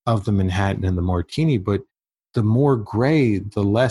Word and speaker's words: Martigny: Where you have Martini and Martinez and Martinez and things that M-A-R-T-I-G-N-Y. Sound like. M-A-R-T-I-G-N-Y. – Right. All types of weird variations. – How do you pronounce that? Martini.